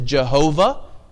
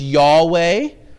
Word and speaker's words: Yahweh: In past centuries, the name of God was pronounced as Jehovah, and still often is. In more recent times, scholars have tended to pronounce it as Yahweh.